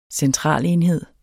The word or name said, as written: centralenhed